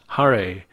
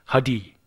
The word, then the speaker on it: Hare: You had a unique pronunciation of the mantra, especially the words Hare, which I always thought you pronounced Hadi.